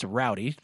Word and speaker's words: Rowdy: You're not going to find a Rudy. – Yeah, not going to find a Rudy. It's Rowdy. It's spelled like, you know, R-O-W-D-I-E.